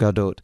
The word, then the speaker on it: Gadot: Pronounce the T. She's Israeli, not French. – Yep. Gadot.